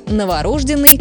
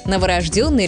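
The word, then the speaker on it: новорождённый: Пишут «е», и ударение начинает гулять. В итоге появляется вариант «новорожденный», который звучит привычно, но считается устаревшим. А правильный вариант один – новорожденный ребенок, новорожденный малыш. Можно запомнить через простую рифму. «Я ужасно утомленный, брат не спит, новорожденный».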